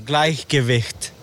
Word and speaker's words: Gleichgewicht: Gleichgewicht. – Yeah, exactly. Gleichgewicht. – So great pronunciation.